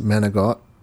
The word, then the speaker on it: manicotti: I kind of wrote my paper on manicotti. – Oh, yeah, you got the pasta entirely wrong, huh? – Mm-hmm. Oh. I'm hearing it now, and I'm a little nervous. – Mm-hmm. It's pronounced manigot.